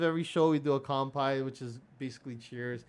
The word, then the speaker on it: kanpai: Actually, in Japanese, it's K-A-N-P-A-I, but, you know, I spell it with an M just because people I guess they pronounce it more with the M, but anyways, Dan, this is to you.